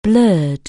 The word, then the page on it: blur: anglais